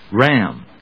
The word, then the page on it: ram: rˈæm